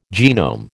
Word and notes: genome: American English

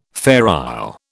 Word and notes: Fair Isle: British English

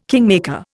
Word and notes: kingmaker: Indian

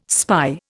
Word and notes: spy: Indian

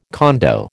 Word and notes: condo: American English

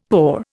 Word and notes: poor: Indian

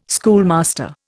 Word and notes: schoolmaster: Indian

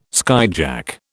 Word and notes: skyjack: British English

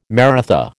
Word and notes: Maratha: American English

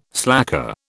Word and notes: slacker: British English

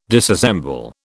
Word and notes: disassemble: British English

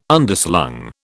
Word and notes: Underslung: British English

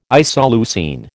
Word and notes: isoleucine: American English

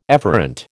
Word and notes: efferent: American English

